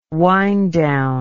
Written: wind-down

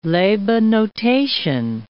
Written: labanotation

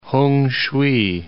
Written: Hungshui

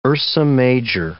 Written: Ursa Major

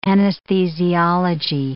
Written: anesthesiology

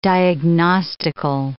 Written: diagnostical

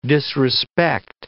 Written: disrespect

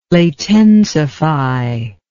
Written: latensify

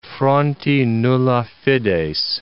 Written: fronti nulla fides

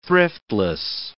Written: thriftless

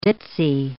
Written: ditzy

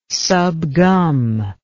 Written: subgum

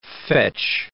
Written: fetch

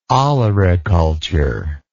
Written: olericulture

